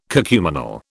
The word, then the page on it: cacuminal: British English